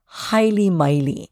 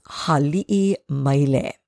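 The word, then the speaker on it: Hali'i Maile (Haliʻimaile): We hear people saying Haili Maile. It is not Haili Maile. It is Hali'i Maile.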